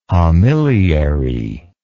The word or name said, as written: homiliary